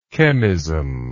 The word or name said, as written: chemism